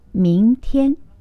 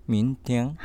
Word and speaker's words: 明天: 明日の明と天気の天と書いて、はい、発音は明天。 – 明天。